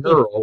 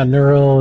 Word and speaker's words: Nurl: But you pronounce it Nurl like girl. – Yeah, Nurl, it's like girl with an N, but it's like IRL with an N in front of it.